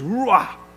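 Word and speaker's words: ruach: So the word for spirit in the Greek is ruah. I'm not saying it properly, but, you know, you have a guttural sound towards the end, which also means spirit or breath or wind.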